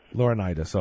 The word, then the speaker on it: Laurinaitis: Joe Laurinaitis, how do you pronounce his name? Laurinaitis? – Laurinaitis, okay. Oh, like Leonidas, okay, like the 300.